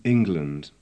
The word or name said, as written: England